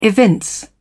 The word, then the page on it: evince: British English